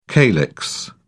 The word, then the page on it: calyx: British English